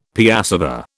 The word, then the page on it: piassava: British English